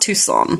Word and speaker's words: Tucson: I know it's spelt Tucson, but I'm sure it's pronounced Tucson.